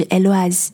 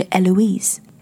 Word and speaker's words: Eloise: 就比如说女孩子的名字，Eloise， Eloise，法式的发音是 Eloise，德式的发音是 Eloise，美式英语的发音是 Eloise。